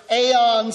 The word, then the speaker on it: Aon: And then lastly, right up the road in Chicago, it's spelled A-O-N, pronounced Aon, I don't know.